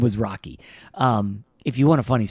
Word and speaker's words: Roki: This superstar, how do you say his name? Is it Roki Sasaki? – I believe it's Roki.